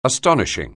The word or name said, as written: astonishing